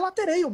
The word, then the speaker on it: latereio: Como é a pronúncia que se fala que vai bater o lateral na área hoje? – É latereio mesmo, Zé. – Latereio. – Lateral então, com escanteio.